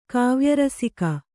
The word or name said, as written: ಕಾವ್ಯರಸಿಕ